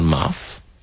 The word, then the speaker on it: Marv: Und der Muffo heißt nicht Muffo, sondern Marv. Ja. – Aha, englisch ausgesprochen und ohne O.